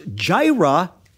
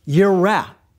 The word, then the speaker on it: Jireh: It's Yahweh, and Jireh is not pronounced Jireh, it's pronounced Yireh.